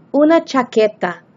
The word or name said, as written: una chaqueta